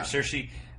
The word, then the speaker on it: Saoirse: Is that how you say it? Yeah. Saoirse.